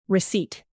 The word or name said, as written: receipt